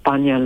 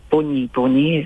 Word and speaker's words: Bunyi Bunyi: Bunya law gatherings, or Bunyi Bunyi is the correct pronunciation, for thousands of years.